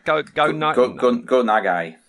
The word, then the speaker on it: Go Nagai: Go Nagai.